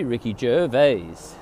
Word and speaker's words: Ricky Gervais: But it's supposed to be Ricky Gervais.